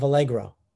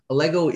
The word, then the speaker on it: Allego: Our next guest is co-founder and president of Allegro.